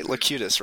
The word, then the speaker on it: Locutus: So, did I pronounce that right? Locutus, right? – Yeah, that's what he said.